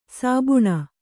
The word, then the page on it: ಸೋಭಾನೆ: sōbhāne